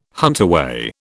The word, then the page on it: huntaway: British English